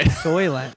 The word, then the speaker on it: Soylent: I mean, yeah, you probably got good organs eating all that Salience, you know? – Yeah, man, couldn't even pronounce it right. – Soylent. Yeah, what was Salient? – Oh, man.